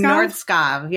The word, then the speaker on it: Nordskov: Nordskov.